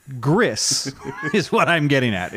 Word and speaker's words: Gris: It's spelled G-R-I-S, but it's Spanish, so it's actually pronounced Gris, which is gray.